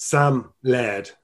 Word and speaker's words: Laird: Welcome to the Healthy Beast, Sam Laird. Now, I pronounced that right. It's L-A-I-R-D.